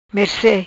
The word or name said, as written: Merce